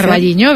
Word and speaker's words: Carballiño: O Carballiño, no lo sé pronunciar. – Carballiño, bien, o exacto.